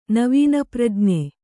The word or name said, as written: ನವೀನಪ್ರಜ್ಞೆ